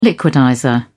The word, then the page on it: liquidizer: British English